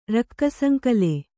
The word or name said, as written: ರಕ್ಕಸಂಕಲೆ